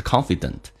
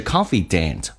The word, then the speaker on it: confidant: 那么你会发现你的 confidant 这个不要搞错，不是 confident，是 confidant 发 a 这个发音，指的是你个人的那么所心爱的一个人 confident。